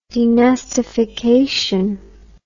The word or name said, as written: denastification